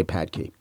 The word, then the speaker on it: Phadke: It was from, hope I'm pronouncing this right, Melinda Padke, P-H-A-D-K-E. Usually people don't go with the F pronunciation in surnames, but if you do, I'm sorry, Melinda Padke. I'm going to say Padke.